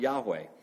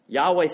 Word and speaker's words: Yahweh: In Hebrew, it's pronounced Yahweh. Yahweh sounds like the Hebrew for, if you were to say, I am in Hebrew.